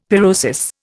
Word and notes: pyrosis: Indian

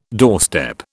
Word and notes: doorstep: British English

